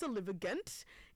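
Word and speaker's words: solivagant: Salivagant, pronounced salivagant,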